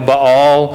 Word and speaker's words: Baal: And let's make an idol to the God who does care about us, which has different pronunciations. It's B-A-A-L, Baal, Baal, whatever it may be.